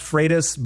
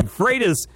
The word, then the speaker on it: Freitas: Technically, it's pronounced Freitas, but my drill sergeant Freitas taught me to respond to anything close. – all right. Freitas is the right way to say it.